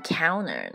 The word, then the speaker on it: counter: 为什么我念的这是念 counter 呢？不念 counter？在美语发音当中，你看到 n 和 t 这两个字母在一起的时候，这个 n t 组合的时候，我们一般会把这个 t 省略，